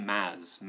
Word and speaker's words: Mads: I think it's pronounced Maz. Maz Mikkelsen.